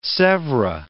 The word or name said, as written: Sevres